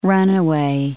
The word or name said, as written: runaway